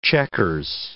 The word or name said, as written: checkers